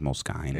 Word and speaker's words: Moleskine: Isn't it Moleskine? – No, well, it's actually pronounced Moleskine.